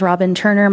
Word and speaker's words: Robin Turner: Mr. Chair, members of the committee, my name is Robin Turner. My name is spelled R-O-B-I-N-T-U-R-N-E-R, and I rise on behalf of the American Civil Liberties Union of Montana in opposition of House Bill 862.